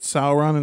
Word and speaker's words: Sauron: Sauron and Saruman? – Yes, you got it, man.